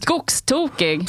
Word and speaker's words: skogstokig: Skogstokig. Is that how it's said? – Skogstokig.